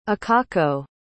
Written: akako

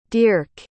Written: dierck

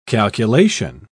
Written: calculation

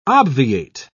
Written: obviate

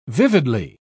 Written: vividly

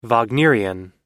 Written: wagnerian

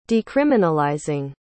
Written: decriminalizing

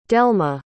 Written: delma

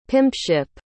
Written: pimpship

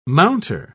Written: mounter

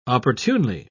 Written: opportunely

